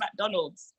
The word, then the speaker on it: McDonald's: It's not Akdarnal, it's called McDonald's.